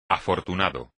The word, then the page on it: afortunado: espagnol